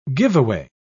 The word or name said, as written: giveaway